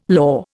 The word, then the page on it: law: Indian